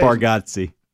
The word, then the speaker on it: Bargatze: How do you say Bargatze. Nate Bargatze.